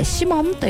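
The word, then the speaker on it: 鰤門: お魚のブリにモンと書きましてシモンという店が銀座に 1店舗、日本橋に 1 店舗ございます。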